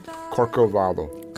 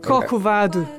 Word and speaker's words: Corcovado: Let's play Corcovado. – Okay, it is, how do you say it? – Corcovado. – Corcovado.